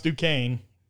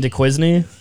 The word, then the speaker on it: Duquesne: Duquesne. That is how you say it. I typed in how to pronounce Duquesne. – You mean DeQuincy? – It's not DeQuincy. DeQuince.